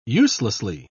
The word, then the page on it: uselessly: British English